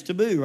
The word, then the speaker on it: Tiebout: and my last name's Taboo, right? So how would you spell it? T-A-B-O-O, makes sense, right? Well, but that's not it, it's French, so it's spelled Tiebout, and she had T-A-B-O-U or something,